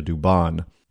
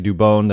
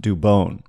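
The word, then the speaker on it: Dubón: Now, I must say that everybody is pronouncing his name wrong, calling him Mauricio Dubon. And it is actually Dubon. There is an accent over the O. And he even confirmed with KNBR's Mark Sanchez that it's pronounced Dubon.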